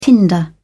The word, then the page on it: tinder: British English